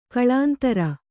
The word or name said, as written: ಕುಲೇಂದ್ರ